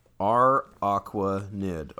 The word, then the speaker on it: Araquanid: Araquanid.